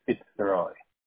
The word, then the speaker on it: Fitzroy: My first name is Fitzroy. – Can you spell that for me, please? – Fitzroy. – Itzroy? – Fitzroy. – Okay. And F as in Frank, right? – Yes.